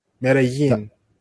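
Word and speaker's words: Medellín: So I'm going to say that we're in, it's pronounced Medellin. Medellin, right? Or is Medellin? – Yin, like a ya. Yin. Medellin? – Medellin. – Yeah, double L.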